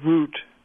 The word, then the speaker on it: route: involved things like saying that the word is not route but root.